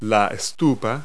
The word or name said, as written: stupa